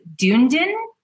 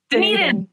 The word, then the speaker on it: Dunedin: Dunedin? Is that it? Is that how you say it? – Dunedin. – Not even.